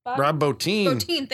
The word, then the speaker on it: Rob Bottin: Rob Bottin.